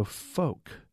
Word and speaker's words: Foch: This is from David, who says, I live near the street in question in Fort Worth, and it's usually pronounced with a long O. So is it Foch or Folk Street? But it's a long O. Let's see. We got an answer on this also from Jay, who says, I pronounce it as if it is rhymed with Coke.